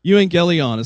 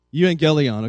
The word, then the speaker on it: Euangelion: It's a Greek word, and it's pronounced... I can't pronounce that. Euangelion is how they pronounce it. Euangelion, okay?